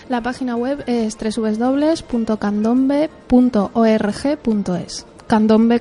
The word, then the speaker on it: Candombe: Asociación de Candombe, si no me equivoco más. Sin más, voy a darle la bienvenida. Buenas buenas tardes. – Buenas tardes. – Lo he dicho bien, ¿no? Candombe, ¿no? – Candombe, sí.